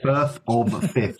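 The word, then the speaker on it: Firth of Fifth: Is it Firth or Fifth? How do you pronounce that? – Firth or the Fifth.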